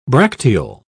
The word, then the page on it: bracteole: British English